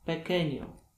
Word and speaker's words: pequeño: Q wymawiane jest jak polskie K, jednak wchodzi tylko i wyłącznie w połączenie QUI, QUE, wtedy wymawiane jest jak ke, ki. Na przykład quince pequeño.